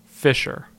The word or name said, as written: Fisher